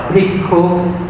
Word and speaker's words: Bhikkhu: Pali, tiếng Pali gọi là Bhikkhu, tiếng này đọc là Bhikkhu, là tỳ kheo, Bhikkhu Ni, Bhikkhu Ni.